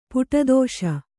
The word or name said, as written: ಪುಟದೋಷ